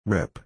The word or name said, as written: rip